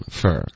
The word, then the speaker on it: for: 然后 for 这个字弱读成 fur，